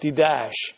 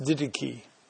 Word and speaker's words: Didache: But the Didache, or when you look at it written, it looks like Didash, but it's pronounced Didache.